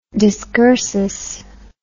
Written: discursus

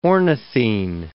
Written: ornithine

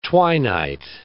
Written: twi-night

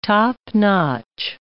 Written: top-notch

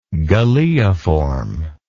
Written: galeiform

